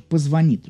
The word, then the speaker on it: позвонит: Меня спрашивают, как правильно говорить «позвонит», конечно же, «позвонит», друзья, да? Мы не воняем, а мы звоним, да?